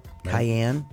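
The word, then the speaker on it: Kyann: It's spelled K-Y. K-Y-A-N-N. Cayenne. – Reminds me of the pepper.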